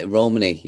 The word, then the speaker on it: Romani: Because I know that you, is it Romani? Is that how you pronounce it? – Yeah, Romani, yeah.